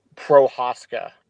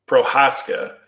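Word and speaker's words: Procházka: Like, how do you pronounce this guy's name? – Prohaska. – Prohaska.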